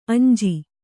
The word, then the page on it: ಅಂಜಿ: añji